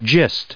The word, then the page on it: gist: American English